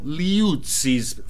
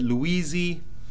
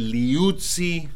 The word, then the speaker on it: Liuzzi: Also, speaking of Toro Rosso, I want to apologize to Tonio Liuzzi's family. I've been pronouncing his name wrong. I've been pronouncing it Luisi, and it's actually Liuzzi.